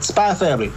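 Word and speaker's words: Spy × Family: I don't know if it's pronounced or not. – For us, like Hunter. You don't say it. – Yeah. But Spy Family.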